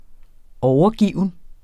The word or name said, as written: overgiven